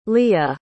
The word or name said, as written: lia